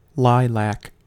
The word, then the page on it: lilac: American English